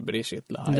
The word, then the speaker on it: Brigitte Lahaie: Ja, jag vet inte om man uttalar det Brigitte Lahaie. – Det är nog så.